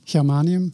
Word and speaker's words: germanium: U, bent ook bezig met geranium, zeg ik het goed? Germanium. Germanium, zo, ja, gallium en germanium.